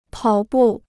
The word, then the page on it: 跑步: pǎo bù